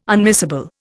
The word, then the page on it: unmissable: Indian